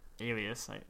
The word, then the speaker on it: alias: You need like a Elias in the fantasy world though too. – Okay. Is that how you pronounce it? Elias? – Alias, yeah.